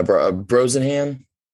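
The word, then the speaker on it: Brosnahan: I don't know how to say her last name. Bronsonhan? – Yeah, Brosenhan.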